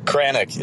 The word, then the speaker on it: Kranick: How do you say his last name? I need to know. – Kranick is his last name.